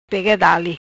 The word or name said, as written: Dale pega